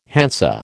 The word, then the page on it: Hansa: American English